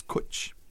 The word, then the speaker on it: cwtch: And I learned what, you know, how to pronounce CWTCH now. You know, it's a Welsh word, and it means like a hug or just to get close to somebody, and it's kwch. What do you think of that? But it's spelled CWTCH.